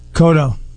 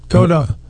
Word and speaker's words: Cotto: If he was down, when he wobbled Cotto that one time, how do you say his name? – Cotto. Cotto.